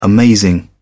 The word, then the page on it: amazing: British English